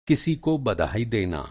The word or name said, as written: बधाई